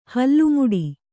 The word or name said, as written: ಹಲ್ಲುಮೊಟ್ಟೆ